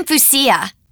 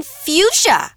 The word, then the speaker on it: fuchsia: Actually, I'm Fuchsia. – I think you mean fuchsia. – No, it's pronounced fuchsia.